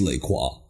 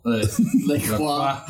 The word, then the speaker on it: LaCroix: It's LaCroix. – LaCroix. How do you spell it? – L-A. Space. CROIX.